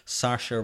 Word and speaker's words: Saoirse: Saoirse yeah, Ronan. That was Apparently, good. I, I had to look this up. Apparently, that's how you pronounce her name, Saoirse Ronan.